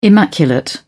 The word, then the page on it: immaculate: British English